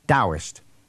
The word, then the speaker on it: Taoist: It is actually written Taoist, T as in Tommy, A-O-I-S-T. So it's written Taoist and pronounced with a D, Taoist.